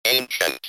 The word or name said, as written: ancient